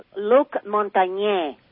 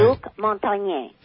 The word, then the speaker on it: Luc Montagnier: And they think that, all these doctors have said, wait until, and by the way, Luc Montagnier is how you pronounce it. – Right. – Luc Montagnier.